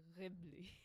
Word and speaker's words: Ghibli: The Arabic pronunciation would be like Ghibli.